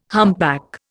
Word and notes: humpback: Indian

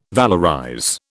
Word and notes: valorize: British English

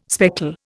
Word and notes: speckle: Indian

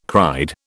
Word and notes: cried: British English

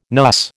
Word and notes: NUS: American English

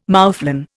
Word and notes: moufflon: Indian

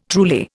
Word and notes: truly: Indian

